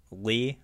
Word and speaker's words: Leigh: I would say Lee.